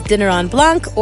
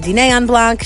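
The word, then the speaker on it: Dîner en Blanc: I'm Hadass Kuznets with Natanya Debona, who is co-host of Philadelphia's Dinner on Blanc, or also you can say Dîner on Blanc.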